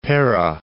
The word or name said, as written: Perak